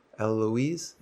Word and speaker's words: Eloise: And then, Mary Ann is also a very popular because it has that three syllable thing, which Eloise has too. Eloise? Yeah, three syllables.